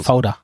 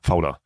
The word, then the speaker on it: Fauda: Ja, Fauda. – Fauda.